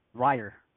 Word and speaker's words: Rahier: Tanner Rahier, is it pronounced Rahier? – It's more like Ryer.